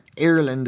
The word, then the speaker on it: Ireland: Americans say ire as in ire. Ireland is closer to how we say it.